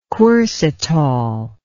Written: quercitol

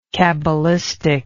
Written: cabalistically

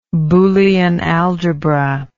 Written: boolean algebra

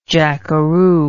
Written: jackeroo

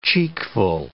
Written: cheekful

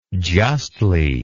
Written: justly

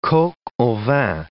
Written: coq au vin

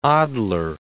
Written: Adler

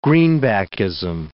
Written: greenbackism

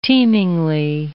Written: teemingly

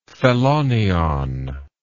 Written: phelonion

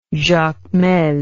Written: jacmel

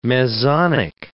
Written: mesonic

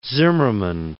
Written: Zimmermann

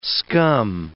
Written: scum

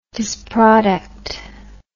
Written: disproduct

